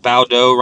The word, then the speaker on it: Baudot: Porter versus Baudot. This one made me laugh because Baudot rhymes with Play-Doh, and both of these guys looked like they were out of shape.